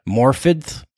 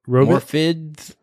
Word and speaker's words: Morfydd: Morfydd? – Robit? Is it spelled Robit? – Morfydd, but she says there's like a TH sound.